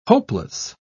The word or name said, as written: hopeless